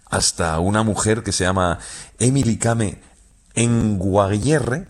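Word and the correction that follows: Kngwarreye: lo he dicho mal. – Emily Kame. – Con Guarreye.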